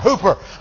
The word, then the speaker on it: huper: The word exceeding in that passage, the Greek word, is spelled transliterated as H-U-P-E-R, hooper.